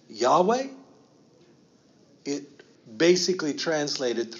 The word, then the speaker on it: Yahweh: That says Yahweh. In my English, I'm going to try and say it like a Hebrew would.